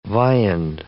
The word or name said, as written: viand